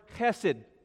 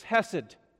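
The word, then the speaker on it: chesed (חֶסֶד): Now that word, steadfast love, is the word chesed. Did I just spit a little bit? Chesed. Okay? The way that we say that in English is just hesed. H-E-S-E-D is how you'd spell that.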